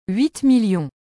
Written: 8000000

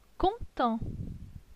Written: Content

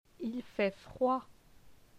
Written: Il fait froid

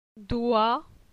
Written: Doi

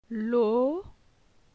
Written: Lo

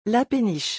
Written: la péniche

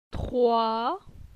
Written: Troi

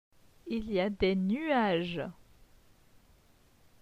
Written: Il y a des nuages

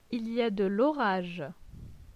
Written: Il y a de l'orage

